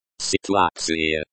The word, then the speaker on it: ситуация: Pay attention to the stress in the words. For example, in ситуация. Ситуация. The stress is on the second syllable.